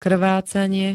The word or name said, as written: krvácanie